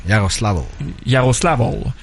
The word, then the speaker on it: Yaroslavl: Et bien sûr, Bob coach le locomotive de Yaroslavl. – Yaroslavl. – Faut prononcer le L à la fin. – Ouais. – Yaroslavl.